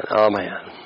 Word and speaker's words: aman: It says, all man, A-M-A-N, pronounced like all, A-W, and all, and then man, emphasized, I mean, the emphasis on the second part, man, all man.